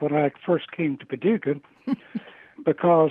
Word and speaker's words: Paducah: In the letter, which is in the Missouri Historical Society, right here, the script changes, and he wrote in capital letters, P-A-D-U-C-A-H, to help in the correct pronunciation.